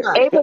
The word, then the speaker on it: Eva: Ava. Ava, because she's Hispanic. It's Ava.